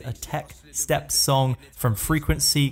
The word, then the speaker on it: FRE4KNC: Let me introduce you to the sounds of Frequency, but that's spelled FRE4KNC. But I looked at the pronunciation just to make sure I didn't sound like an asshole on air. It's pronounced Frequency, everybody.